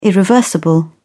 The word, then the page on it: irreversible: British English